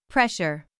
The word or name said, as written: pressure